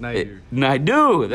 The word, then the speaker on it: Naidoo: I believe I said the name right. – Almost, Naidoo.